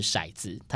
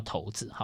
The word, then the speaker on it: 骰子: 扫视那些我曾拥有的街道，我曾经可以随手掷下命运之头，那个头是骰子，它的正确读音叫骰子。